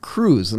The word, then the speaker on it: Qreuz: So I have this other show called E-commerce Undercover, and we had this guy from Germany on, and the name of his company is Q-R-E-U-Z, and he pronounced it Cruise.